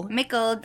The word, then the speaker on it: Mickle: Mickle. – Rhymes with Zickle. M-I-C-K-L-E.